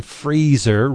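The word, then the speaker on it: Frazer: And the cover art is by one Freezer, rhymes with laser, Irving.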